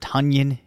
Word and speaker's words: Tonyan: Let's talk about Robert Tonyan Jr. And apparently you do pronounce it that way, much like Funyuns, which yes.